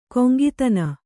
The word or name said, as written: ಕೊಂಗಿತನ